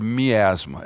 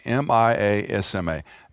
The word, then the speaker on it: miasma: We have to fast forward quite a bit, and there's a term in the medical realm called, it, it sounds like asthma, but it's the word miasma. It's spelled a little differently M I A S M A, miasma.